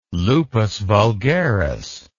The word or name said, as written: lupus vulgaris